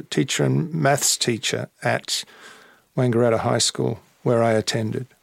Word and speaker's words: Wangaratta: Did she still live in, I'm going to say this wrong, Wangaratta? Wangaratta? – No, she lived in Melbourne. I lived in Wangaratta till I was, I think, 12, and then left and went to Melbourne.